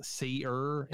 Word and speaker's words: Sear: Cole Sear, pronounced Seer, is able to see things.